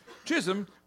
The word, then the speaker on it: Chisholm: Chisholm, which, yes, rhymes with jism.